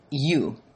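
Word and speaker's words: eu: So the Greek words bless and blessing come from the root good word, you, E-U, it's pronounced you, which is good, and logos, good word, and the word is logos in the Greek.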